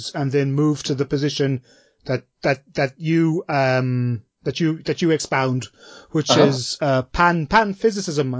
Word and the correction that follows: panpsychism: I hope I'm saying that right. – Panpsychism. Pronunciation. That's all right.